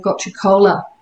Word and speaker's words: gotu kola: Another example is I think a lot of people have heard of gotu kola, I never know how to pronounce it right. – No, you've got it right.